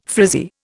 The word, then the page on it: frizzy: Indian